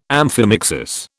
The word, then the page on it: amphimixis: British English